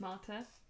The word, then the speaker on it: Marte: My name is Marte, and, you know, Norwegian, which is where I am from, Norway. It's pronounced Marte.